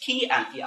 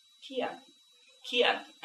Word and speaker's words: Chianti: Leute gibt es, kann ich euch sagen, die hat tatsächlich behauptet, das war so ein Chianti. Dabei ist das ganz klar, wenn also nach einem CAH kommt, dass das Chianti ausgesprochen wird. Chianti.